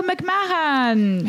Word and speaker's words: McMahon: And we have a fellow survivor and comedian, surprise, Joe McMahon.